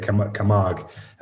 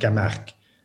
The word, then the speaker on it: Camargue: Well, in English, you would say Camargue. It's actually pronounced Camargue. Because it's a place in the south of France.